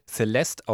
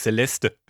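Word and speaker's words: Céleste: Das wird Celeste ausgesprochen und nicht Celeste, wie ich.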